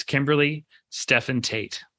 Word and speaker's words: Kimberly Stephan Tate: And today, my guest is Kimberly Stephan Tate.